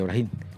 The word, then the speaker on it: Brahim: O Brahim. Así se pronuncia, Brahim.